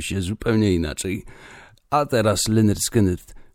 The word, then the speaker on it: Lynyrd Skynyrd: I oni modulowali to imię i nazwisko, mówiąc o nim Leonard Skinnerd, a przy odpowiedniej wymowie wychodziło po prostu Lynyrd Skynyrd.